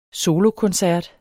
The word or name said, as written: solokoncert